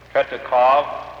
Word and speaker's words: Tretyakov: While you were in Berlin, did you meet, while in Moscow, did you meet Sergei T-R-E-T-Y-A-K-O-V? T-R-E-T-Y-A-K-O-V.